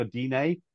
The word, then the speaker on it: Digne: He was at the ball before, um, how do you pronounce it? Digne or Dine? – Yeah. Dine, I think.